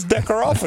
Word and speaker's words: Deckerhoff: I feel like you guys said Decker off the other day. – Yeah, I was a little strong in that, but I think you're supposed to eat the H on that. It's there, but you're just supposed to. It's like a silent H.